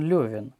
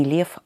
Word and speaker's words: Лев: Толстой называл себя не Лев, а Лев. То есть имя Лев иностранного происхождения, и крестьяне, простой народ часто его произносили как Лев. И поэтому и сам Толстой, и члены его семьи, и близкие друзья к нему обращались как Лев Николаевич.